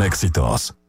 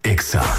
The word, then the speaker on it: Exa: No se dicen éxitos. Se dice Exa.